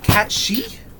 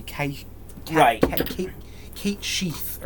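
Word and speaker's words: Cat Sìth: You're Cat Sith for now. – apparently you pronounce that Cat She. – I think. – Like, because it's Gaelic or something like that. Like, it's supposed to be Kate Sheath or something like that.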